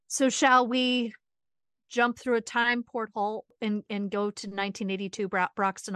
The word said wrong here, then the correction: portal: Did you say porthole? – Portal.